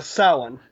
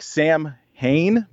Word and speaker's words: Samhain: Samhain. – Samhain. It's spelled, it looks like Sam Hain, but it's Samhain.